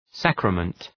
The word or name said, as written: sacrament